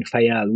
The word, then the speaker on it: Faial: And is it Faial? Is that how you say it in the Azores? I visited there by boat many years ago, but yeah. Exactly. Yeah, we are now in Faial.